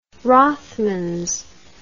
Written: Rothmans

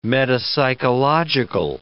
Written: metapsychological

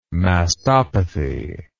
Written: mastopathy

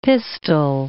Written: pistol

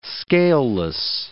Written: scaleless

